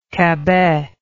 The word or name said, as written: Cabet